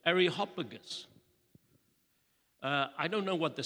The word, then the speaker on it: Areopagus: And in the Greek, if we transliterate the word, it's called the Areopagus. Now, there are different ways of pronouncing this word. The British would say Areopagus, like a Cockney Irishman, Areopagus.